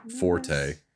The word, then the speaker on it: forte: It is not her forte. – An intelligence fix, you said. Yes. – Apparently it's supposed to be pronounced fort. – Yes, what? But nobody does.